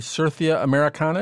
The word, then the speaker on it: Certhia americana: His track is called, if I'm pronouncing this correctly, Certhia Americana. Is that right? – That is correct.